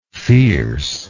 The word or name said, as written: fierceness